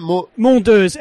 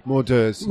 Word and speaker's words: Mondeuse: How do you say that? – Mondeuz, M-O-N-D-E-U-S-E. – Mondeuz.